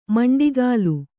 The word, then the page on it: ಮಂಡಿಗಾಲು: maṇḍigālu